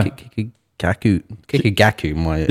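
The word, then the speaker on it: Kikagaku Moyo: And it's kind of interesting because our last episode was Kikugayo Mayo. Am I saying that correctly? – No. – Kikugaku Mayo. – Yeah.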